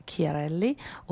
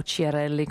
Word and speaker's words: Chiarelli: Lei viene da Toronto, è di origine italiana, si chiama Rita Chiarelli, o Chiarelli come la pronunciano qua,